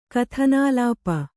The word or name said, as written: ಕಥನಾಲಾಪ